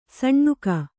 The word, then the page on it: ಸಣ್ಣುಕ: saṇṇuka